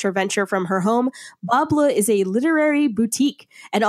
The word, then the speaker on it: Bas Bleu: So our sponsor is Bas Bleu, which is spelled B-A-S-B-L-E-U.